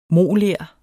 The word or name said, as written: moler